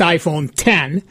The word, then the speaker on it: iPhone X: The iPhone X, pronounced iPhone 10, is Apple's $1,000 anniversary device with an edge-to-edge OLED display, facial recognition, and no home button.